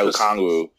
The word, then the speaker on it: Okongwu: I like the guy you talked about before, the I can't Okongwu? Say his name. Yeah, Okongwu.